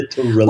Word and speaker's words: literally: Literally or literally, I said it's three syllables, it's not four. Why are you insisting and putting another syllable in there? – Yes. You know, literally.